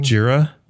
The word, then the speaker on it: Jira: Jira. – Jira, like G. – J-I-R-A. – Okay, I see. – And it's short from Gojira, which is Godzilla.